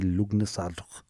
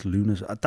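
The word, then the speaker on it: Lughnasadh: Well, phonetically, it'd be Lugnasad. – Oh, no, yeah, it would. – It's Lunasad.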